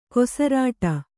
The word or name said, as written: ಕೊಸರಾಡು